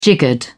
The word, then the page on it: jiggered: British English